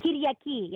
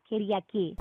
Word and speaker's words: Kyriaki: How do you say your Greek name? Is it Kyriaki? – Kyriaki, yeah. Kyriaki.